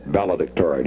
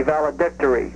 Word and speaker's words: valedictory: I think the accented syllable is D-I-C, valedictory.